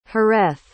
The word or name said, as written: jerez